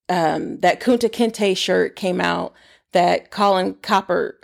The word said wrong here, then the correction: Kaepernick: uh, Kaepernick, I can't say his name, sorry, Kaepernick had on.